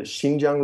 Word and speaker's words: Xinjiang: Yeah, it's the Xinjiang region, which is the far northwestern.